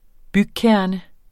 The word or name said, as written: BYGKERNE